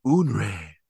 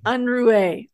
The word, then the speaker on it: Unruhe: So, episode 4, Unre. How do you say it? I forgot how to say it already. It's been so long. Unre. – Unruhe.